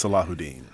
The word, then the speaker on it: Salahuddin: Yes, it's Salahuddin. Yeah. For those of you who are fans of iambic pentameter, the emphasis on the second and fourth, so it's what light from yonder wins Salahuddin.